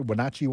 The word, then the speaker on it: Wenatchee: I'm probably not saying that right, but I'm going to try Wenatchee, Washington.